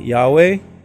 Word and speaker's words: Yahweh: However, there are three recognized pronunciation and spelling which are one Yahweh, which is Y A H W E H. The second is Y A H A V E and the third is Jehovah. The, for, the preferred pronunciation is that most people use is Jehovah, but everyone else in the Hebrew tongue will say Yahweh.